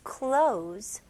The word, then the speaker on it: clothes: His clothes got wet. Okay? 여기서도 똑같이 발음을 clothes 이렇게 할 필요 없습니다. Z 발음으로. His clothes got wet.